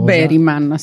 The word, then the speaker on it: Bergman: O Bergman, se lo vogliamo proprio pronunciare alla svedese.